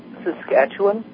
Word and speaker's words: Saskatchewan: Saskatchewan, Sask.